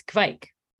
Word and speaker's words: Kveik: And I'm pronouncing it correctly, right? It's Kveik.